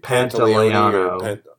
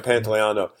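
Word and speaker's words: Pantoliano: Pantaleano. – Pantaleano.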